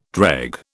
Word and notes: dreg: British English

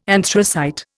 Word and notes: anthracite: Indian

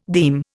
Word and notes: deme: Indian

